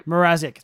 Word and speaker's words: Mrazek: You know, like we saw it right in the start of the season with Mazurek. How do you say his name? – Mrazek.